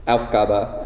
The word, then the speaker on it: Aufgabe: The emphasis is on the first syllable, Aufgabe, and the last syllable is kind of like a schwa.